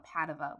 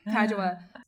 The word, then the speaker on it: Padua: I keep wanting to call her Padova, which is how you say it in Italian, but Padua.